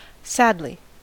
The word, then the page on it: sadly: American English